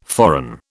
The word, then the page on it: foreign: British English